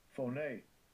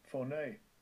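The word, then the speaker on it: Phoné (φωνή): Phoné. Phoné.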